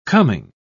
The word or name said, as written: coming